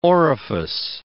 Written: orifice

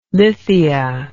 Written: lithia

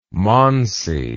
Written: Mansi

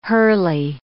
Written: hurly

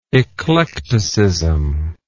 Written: eclecticist

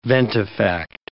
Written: ventifact

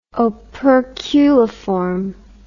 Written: operculiform

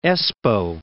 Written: Espoo

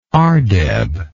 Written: ardeb

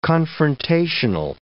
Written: confrontational

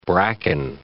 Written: bracken

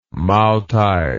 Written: maotai

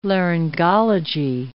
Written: laryngology

